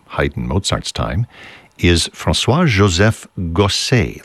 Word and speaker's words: Gossec: Last name is spelled G-O-S-S-E-C. You might think that would be pronounced Gosek, but we know that he himself pronounced it that way because he would spell his name differently, sometimes with an E-Z on the end, an E-T, whatever it is.